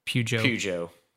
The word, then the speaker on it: Peugeot: Is it Peugeot, I guess is how you say it, Cadillac, Alpine, or Ferrari? – Peugeot. – That is correct.